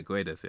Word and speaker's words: Guedes: Yeah, Guedes. Yeah. – Guedes, okay.